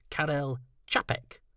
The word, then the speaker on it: Čapek: Yes, it should be Karel Čapek.